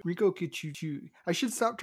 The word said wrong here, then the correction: Rinko Kikuchi: Rinko Kikuchi.